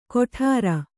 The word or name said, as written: ಗೊಟರ್